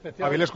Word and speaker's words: Avilés: Bueno, Avilés, creo que se llama Avilés. – Sí. – Avilúa. Acento en la E. – Avilés, correcto.